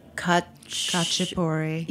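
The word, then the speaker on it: khachapuri: Now, what is, I'll try to pronounce this one. – Kachapuri. – Yes.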